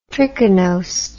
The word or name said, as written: trichinosed